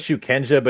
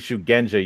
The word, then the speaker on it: shugenja: And they pronounce it as it's written as shukenja, but it's supposed to be shugenja, you idiot.